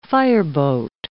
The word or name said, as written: fireboat